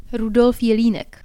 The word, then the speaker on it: Rudolf Jelinek: Rudolf Jelinek.